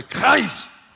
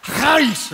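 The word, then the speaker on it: charis (χάρις): Everybody say charis. You got to say it like you're hacking a loogie. Trust me, that's the way you say it. Charis, okay?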